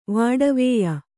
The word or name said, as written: ವಾಡವ್ಯ